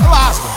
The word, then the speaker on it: Lasgo: Falei certo, é Lasgo? É, Lasgo.